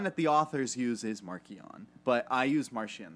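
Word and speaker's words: Marchion: The authors pronounce it Markian. But when they gave Mark Thompson the audiobook, they didn't tell him the right pronunciation, so he said Martian.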